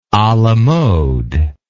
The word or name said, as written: a la mode